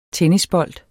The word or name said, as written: tennisbold